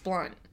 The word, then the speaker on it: Blount: Here in North Carolina, we've got a Blunt Street, and it's spelled Blount, but it's pronounced Blunt.